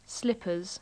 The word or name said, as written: slippers